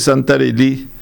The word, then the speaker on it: Santarelli: Voy a hablar, ya que estamos en víspera de nuestro encuentro deportivo con Francia, el señor Santarelli, cuyo nombre pronunciamos en francés Santarelli,